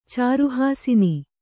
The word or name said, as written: ಚಾರುಹಾಸಿನಿ